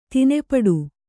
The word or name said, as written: ದಾನಪಟ್ಟೆ